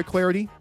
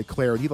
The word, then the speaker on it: Clardy: I'm Troy Clarity. You can follow me on Twitter, at Troy Clarity. The last name is spelled C-L-A-R-D-Y.